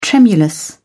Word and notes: tremulous: British English

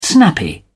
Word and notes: snappy: British English

